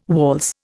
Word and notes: waltz: Indian

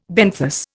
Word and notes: benthos: Indian

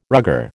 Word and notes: rugger: American English

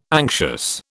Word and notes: anxious: British English

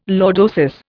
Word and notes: lordosis: Indian

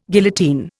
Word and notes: guillotine: Indian

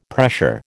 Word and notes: pressure: American English

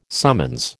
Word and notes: summons: American English

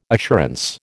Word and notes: assurance: American English

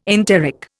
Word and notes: enteric: Indian

